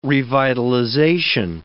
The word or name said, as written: revitalization